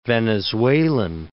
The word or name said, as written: venezuelan